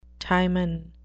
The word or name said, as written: timon